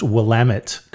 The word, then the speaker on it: Willamette: Nick Cage pronounced Willamette correctly.